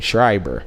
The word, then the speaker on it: Schreiber: Then they go, of course, to commercial, come back from break, and Sarah Schreiber is from my understanding, that's how you pronounce her name, Schreiber, tries to interview Shane McMahon and Drew McIntyre backstage, but The Miz appears.